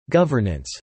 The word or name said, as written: governance